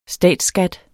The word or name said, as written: statsskat